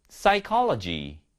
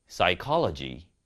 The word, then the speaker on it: Psychology: Psy, Psy, Pseudonym, Pseudonym, Pneumonia, Pneumonia, Psychology, Psychology.